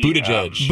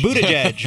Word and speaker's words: Buttigieg: Pete, Buttigieg. – Buttigieg. We learned it. – Mayor Pete. – I practiced it 20 times before we interviewed him. – Buttigieg.